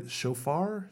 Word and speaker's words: shofar: am I pronouncing this right? Shofar? – Yeah, that is right, yeah.